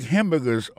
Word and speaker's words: hamburger: On a remarqué que dans le mot hamburger, ça a comme d'hab dit, il y a trois syllabes là-dedans. Quand on appuie et puis plus ça, c'est sous premier syllabe là.